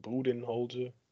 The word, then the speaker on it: Budenholzer: There's a reason why Mike Budenholzer, I think I'm saying his name right. – Budenholzer. – Close enough.